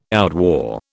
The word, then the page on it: outwore: British English